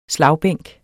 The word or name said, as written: slagbænk